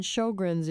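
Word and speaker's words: Sjögren: And Sjogren's, if you're just listening and can't see the word, is spelled S-J. Oh, with a funny, umlaut or something, G-R-E-N, Sjogren,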